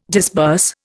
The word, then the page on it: disburse: Indian